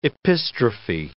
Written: epistrophe

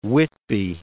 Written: Whitby

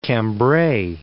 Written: Cambray

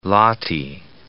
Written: lati

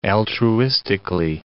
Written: altruistically

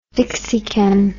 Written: dixican

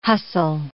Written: hustle

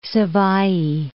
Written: Savaii

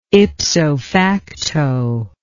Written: ipso facto

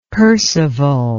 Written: percival

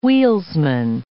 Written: wheelsman

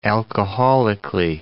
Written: alcoholically

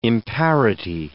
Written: imparity

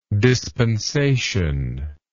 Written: dispensatorily

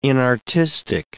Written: inartistic

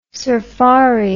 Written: surfari

